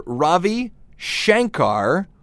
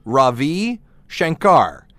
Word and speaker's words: Ravi Shankar: It's either Ravi Shankar or Ravi Shankar. So you have to put the emphasis on the right syllable, apparently.